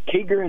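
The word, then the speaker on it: Kieger: Keeger, is that how you pronounce it? – Yes, it is. Yep.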